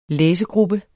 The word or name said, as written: læsegruppe